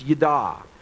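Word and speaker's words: yada (יָדַע): The Hebrew word that's translated know would be pronounced yada.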